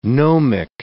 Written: gnomic

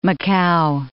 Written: Macau

